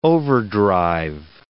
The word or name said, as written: overdrive